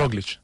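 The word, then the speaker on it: Roglič: Mira, ya que te tengo, Roglic, tampoco sé pronunciar Roglic, ¿cómo lo tenemos que pronunciar? – Roglic. – Roglic, ah, sí, bien. Este sí.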